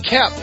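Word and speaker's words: Koepp: We have the former screenwriter of the first Spider-Man movie, David Coep, is that how you say it? He thinks there should be three different Spider-Man movies. – David Kep. – Kep, that's how you say it.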